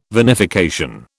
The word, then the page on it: vinification: British English